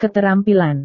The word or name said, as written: keterampilan